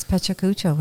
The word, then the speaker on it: Pecha Kucha: We've been practicing Pecha Kucha for a while. Though we were saying Machu Picchu, Pokemon Ipuchu, Pichu Kuchu. We weren't sure. But now it's Pecha Kucha.